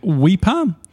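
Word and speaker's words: Weipa: It is pronounced like Weeper. It's this is where she lives in Weeper.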